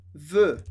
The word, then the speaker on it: veut: Aqui, diferente de je veux, tu veux, que escreve igual, aqui a gente tem a terceira opção, que é com T no final. Elle veut, que a gente pronuncia da mesmíssima maneira. Elle veut, o som E.